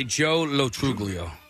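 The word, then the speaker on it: Joe Lo Truglio: We also have, and tell me if I'm saying this correctly, Jolo Truglio. – I would say Joe Lotruglio.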